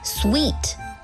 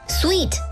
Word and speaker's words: suite: 如果是多人旅行，或者是全家出游，你还可以考虑订上一间套房 suite，注意发音是 suite，S-U-I-T-E， suite， suite 通常包含卧室和客厅，豪华的 suite 还会有小厨房哦。